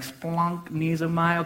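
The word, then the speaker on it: splanknesomai (σπλαγχνίζομαι): It's like if you get something kind of stuck in your throat and you're like, splanknesomai, okay? That's how you say it.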